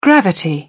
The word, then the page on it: gravity: British English